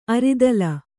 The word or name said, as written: ಅರಿದಲ